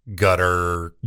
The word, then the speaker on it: Qatar: How do you say the host country? My wife and I were going back on... – Qatar, gutter. – Guitar. – Guitar. – Guitar. Gutter, I heard like an actual guy say it because we searched it last night after we had the, she's like, it's Qatar.